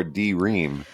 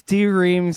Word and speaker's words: D:Ream: He was also the keyboard player for, do you pronounce it Dream or D-Ream? – I think it's D-Ream. So in the Conan O'Brien interview I listened to with him, he said it's spelled D-Ream, but it's pronounced Dream. It's Dream with punctuation.